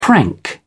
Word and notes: prank: British English

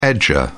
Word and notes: edger: British English

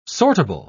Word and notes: sortable: British English

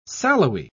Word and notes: sallowy: British English

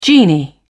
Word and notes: genie: British English

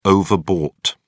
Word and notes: overbought: British English

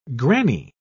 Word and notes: grannie: British English

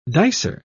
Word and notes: dicer: British English

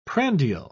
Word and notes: prandial: British English

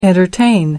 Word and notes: entertain: American English

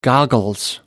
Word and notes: goggles: American English

